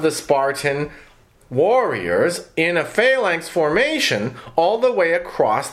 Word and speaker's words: phalanx: The flanx. – Pronounced phalanx.